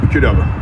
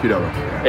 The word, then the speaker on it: Qdoba: Cordoba. – Qdoba. Yeah. – I said that wrong? – Yeah. – Qdoba? – Qdoba.